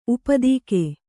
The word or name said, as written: ಊಪೋದಿಕೆ